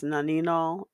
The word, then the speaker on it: nonenal: There's a chemical that is associated with people smelling old, and it's called nonenol. I've heard it pronounced nonenol and nonenol. N-O-N-E-N-A-L, or 2-nonenol.